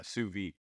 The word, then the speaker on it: sous-vide: You had the sous-vide, is that what I say it right? – Yeah, sous-vide.